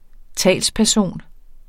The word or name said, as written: talsperson